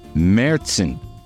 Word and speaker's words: Märzen: Now featuring two locally made German-style Märzen beers. I said it right. They have the umlaut over the A like Motley Crue.